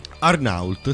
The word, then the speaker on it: Arnault: Immagino che si pronunci così. Allora, si è scritto Arnault, sì. – Arnault, sì, vedi, l'ho pronunciato bene.